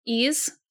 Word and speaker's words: Ys: Ease. I'm pretty sure that's how you pronounce it. It's YS.